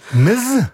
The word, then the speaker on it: Ms: Maar wat je ook vaak op ziet komen is het overkoepelende Ms. En dat spreek je dan uit met een Z, maar je schrijft het als MS. – Ms? Ms. Okay.